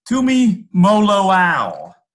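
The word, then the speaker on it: Tuimoloau: I guess I don't blame you, but Tumaloau. – Close. Tumi Moloau. – Tumi Moloau. Okay. – Tui? Yeah, JT Tui Moloau is how he pronounces that.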